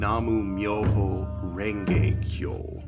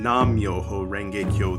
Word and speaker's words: Namu Myoho Renge Kyo: You might be familiar with chanting Nam-myoho Renge Kyo. There's a little bit of pronunciation difference, but not a dogmatic difference or difference in meaning between those two.